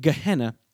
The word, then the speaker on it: Gehenna: This word Gehenna is fascinating.